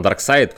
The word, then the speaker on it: Darkseid: То он Дарксид все-таки? – Нет, он Дарксайд, потому что он, типа, он ч- говорится Дарксайд, произносится Дарксайд, он просто пишется как-то по-другому, по транскрипции, вроде, вроде как.